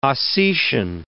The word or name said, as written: Ossetian